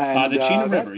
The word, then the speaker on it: Chena River: how do you pronounce it, the Chena River? – The Chena River, yes.